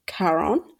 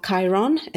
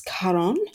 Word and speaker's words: Charon: It's a person called Charon, not Chiron, it's Charon.